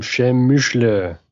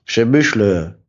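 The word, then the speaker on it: Przemyśl: Wir hatten, endlich haben wir die Aussprache rausgefunden. Chemischle. – Chemischle.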